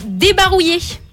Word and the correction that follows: débarroulé: Si, on dit même débarroulé si c'est gaga. – Alors c'est gaga. – C'est gaga. – Débarroulé. J'ai mal prononcé.